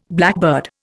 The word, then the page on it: blackbird: Indian